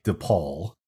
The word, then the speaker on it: DePauw: It sounds like DePaul, but it's spelled DePaul, DePauw University, where he was one of 100 awarded the Edward Rector Scholarship, giving him full tuition.